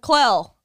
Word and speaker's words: Clell: Hey, how do you say your name again, man? – Clell. – Clell? – C-L-E-L-L.